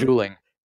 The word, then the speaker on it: Juling: Another one is, and here's another one I'll have to ask you if I'm pronouncing it correctly, Juling Crater? – Juling, right.